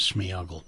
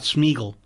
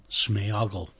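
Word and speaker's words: Sméagol: His name properly, of course, is Smeagol. – Yeah. – Not Smeagol, Smeagol.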